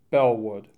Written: Bellwood

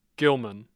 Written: Gilman

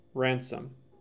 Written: Ransom

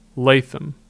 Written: Latham

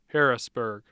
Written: Harrisburg

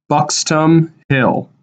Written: Buxtom Hill

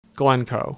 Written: Glencoe

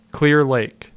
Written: Clear Lake

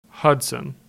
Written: Hudson

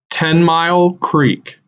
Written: Ten Mile Creek

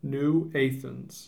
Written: New Athens

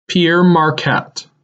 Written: Pere Marquette

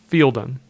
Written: Fieldon

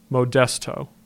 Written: Modesto

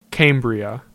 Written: Cambria

